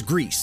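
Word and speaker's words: Gris: And they said that Grease is the correct way to say the name of the game. So it is Grease.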